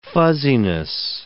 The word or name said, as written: fuzziness